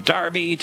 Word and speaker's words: Derby: Spelled D-E-R-B-Y, but most English people describe it as Derby. They pronounce it that way? – They do indeed, except that the local people in the villages in Derbyshire call it Derby.